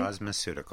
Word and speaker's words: cosmeceuticals: cosmeceuticals. Did I say that correctly? – Cosmeceuticals. – Cosmeceuticals. Very nice.